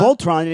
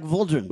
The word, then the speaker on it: Voltron: You drive the panda like a Voltron, and you pronounce Voltron like Voldron.